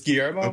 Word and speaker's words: Guillermo: It's Guillermo, okay.